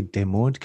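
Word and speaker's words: démodé: It's French. So I want to say Demode because fashion.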